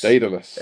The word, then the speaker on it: Daedalus: And now we have Project Daedalus. – Daedalus.